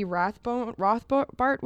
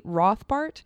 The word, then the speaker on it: Rothbart: Found is this zine that became a book that this guy Davy Rothbart was doing. Sorry, I totally mispronounced his name now. Davy Rothbart.